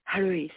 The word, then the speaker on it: Charis: Charis. It's spelled C-H-A-R-I-S.